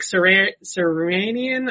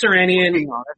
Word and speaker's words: Sirianni: They have decided to hire Colts offensive coordinator Nick Sirianni, I think it is. – I have never heard that name pronounced. I was hoping you knew it. – I think it's Sirianni.